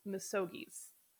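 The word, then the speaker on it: misogi: So I know one of the ways that you have pushed back against comfort culture is through misogies. I said that. Did I say that right? – You said that right.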